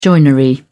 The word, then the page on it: joinery: British English